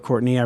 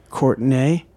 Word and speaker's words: Courtnay: It's actually Courtnay or Courtney. I respond to both, but it's spelled Courtnay.